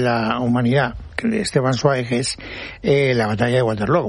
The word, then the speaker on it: Zweig: Sí, pero desde que el bardo nos ha llevado por el camino bien, ahora hay que decir Zweig. – Zweig. – Es así como se pronuncia en alemán.